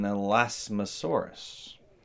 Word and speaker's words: Elasmosaurus: So now you have this idea that maybe Nessie is an Elasmosaurus. Did I say it right that time? – Yeah, Okay, good. – you said it good.